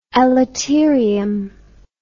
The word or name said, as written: elaterium